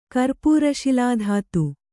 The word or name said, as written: ಕರ್ಪೂರಶಿಲಾಧಾತು